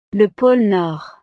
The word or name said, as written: le pole nord